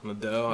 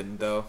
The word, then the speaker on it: Nadal: How do you pronounce the last name? Nadal? – Nadal. I would probably say Nadal.